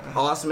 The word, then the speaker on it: Aust: Austin, man, go ahead, you know. – What's going on? I'm Austin. You can find me on Twitter, Lowkey Aust. Spell it how it sounds.